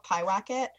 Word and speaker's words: Pyewacket: So because I really like Backcountry, that's why I decided to kind of give his other movie a shot, which is called Piwacket. It's spelled Pyewacket.